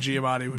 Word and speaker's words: Giamatti: And then I want the whole thing to just be like Giamatti. Did I say it wrong? – Yeah. No. I'm agreeing with you. – He's saying Giamatti.